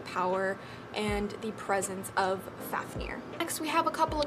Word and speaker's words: Fafnir: Next, we have Fafnir, the Elemental Dragon Guardian of Fire, and this is spelled F-A-F-N-I-R, but is pronounced F-A-F-N-E-E-R, like near, not like Nair, because that's kind of how it's spelled, so we're going to call him Fafnir instead of the way it's like spelled.